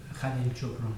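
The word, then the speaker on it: Khalil Gibran: Khalil Gibram.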